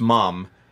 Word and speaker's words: ma'am: Ma'am is pronounced mom in British, and then mom is mum. So it's easy to get the two confused.